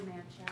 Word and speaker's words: Manchel: Sue Manchel. – How do you spell it? – M-A-N-C-H-E-L.